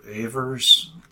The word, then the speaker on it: Ewers: Today's story is going to be by Heinz Ewers, or Ewers, the way it's pronounced in German.